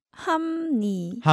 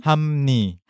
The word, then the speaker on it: 합니: So when you want to open your mouth again from that closed shape and you want to pronounce 니, what does it become? – 함니. – 함니. – 함니. – There's no room for the sound of P. Because yes. – your mouth is shut and then opened again.